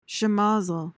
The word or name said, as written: schemozzle